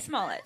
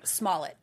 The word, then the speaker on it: Smollett: Smollett. – I've been hearing Smollett.